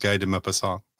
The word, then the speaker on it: Guy de Maupassant: I mispronounced Guy de Maupassant.